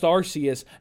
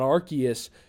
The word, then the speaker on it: Arceus: Now next week when we record Pokemon Legends Arceus, and yes, Matthew, it is pronounced Arceus, not Arceus.